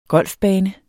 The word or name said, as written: golfbane